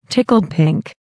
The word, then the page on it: tickled pink: American English